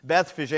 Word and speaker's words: Bethphage: 1a, now when they drew near to Jerusalem and came to, and it's actually pronounced Bethphage, like Fabergé, you've heard of that? Bethphage, and I was calling it Bethphage.